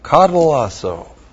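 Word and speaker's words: katallasso: Now, even though we were enemies, if we were reconciled to God, and the word there is katalasso, looks like that in the Greek, K-A-T-A-L-L-A-S-S-O. S. Long O. Catalasso.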